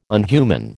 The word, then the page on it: unhuman: American English